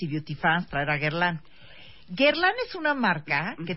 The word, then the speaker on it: Guerlain: Eric Corral, director comercial de Guerlain. ¿Se dice bien? ¿Lo dije bien? – Sí, es Guerlain. Guerlain, Guerlain.